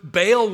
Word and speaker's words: Baal: Jotham had condoned idolatrous practices, which opened the way for Ahaz to encourage Baal worship, as it's often pronounced.